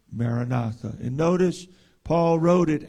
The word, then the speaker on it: Maranatha: In Aramaic, it is pronounced Maranatha, Maranatha, which means the Lord cometh.